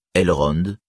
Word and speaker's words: Elrond: Ainsi, Elrond ne rime pas avec plafond, mais avec l'anglais pond. Voyelle.